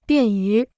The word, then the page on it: 便于: biàn yú